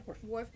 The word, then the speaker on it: Worf: Then I said Worf. Did I say that right? – Yeah, Worf.